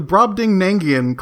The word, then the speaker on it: Brobdingnagian: She was not massive like the Brobdingnagian clitted babes of today.